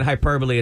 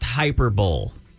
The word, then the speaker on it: hyperbole: Yeah, well, you're mispronouncing it, calling it hyperbole. It's pronounced hyperbole.